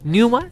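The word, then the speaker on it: Pneuma: Neuma se escribe P-Neuma. Así, P-Neuma.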